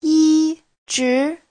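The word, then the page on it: yizhi: yi1zhi2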